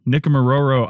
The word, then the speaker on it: Nikumaroro: They recently completed, as I said, their summer trip to, and you'll have to correct my pronunciation on this, but Nikumaroro Island, and we're going to talk about what they learned. So welcome to the show, Tom. – Thank you. You pronounced that very well.